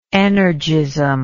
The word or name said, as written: energism